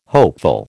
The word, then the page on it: hopeful: American English